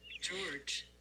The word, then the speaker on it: Georges: George. – It's George. – That would be George. – George. – George. – Well, you say it like Z-H-O-R-S-H.